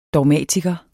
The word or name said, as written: dogmatiker